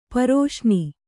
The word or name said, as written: ಪರಸನೆ